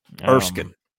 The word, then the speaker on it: Erskine: a 40-point victory on Monday against Erskine. Is that how you say it? – Erskine.